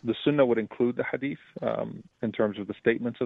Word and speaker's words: Hadith: And then there's, and I hope I'm pronouncing that correctly, the Hadith, which is a collection of sayings attributed to the Prophet Muhammad. Am I right about that? – Correct.